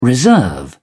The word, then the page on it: reserve: British English